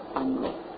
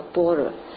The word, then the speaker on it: 般若: 今天我们要谈的是波尔与生活智慧，这个字不叫不念作般若，念作波尔，